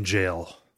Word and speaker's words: Gaol: Callum says the Hypogean Gaol in Bloodborne. I don't know if I'm pronouncing that right. – Hypogean Jail.